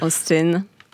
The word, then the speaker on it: Austen: Mais bon, Jane Austen, c'est vraiment le E là, est entre le I et le E.